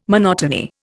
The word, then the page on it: monotony: Indian